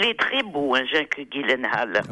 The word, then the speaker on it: Gyllenhaal: En tout cas, vous avez évidemment vu le film Prince of Persia, Les Sables du Temps, avec Jake Gyllenhaal, car il faut prononcer Gyllenhaal et non pas Gyllenhaal comme je l'ai fait hier ou avant-hier.